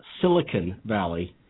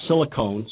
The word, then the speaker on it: Silicon Valley: And by the way, it's Silicon Valley. It's not silicone.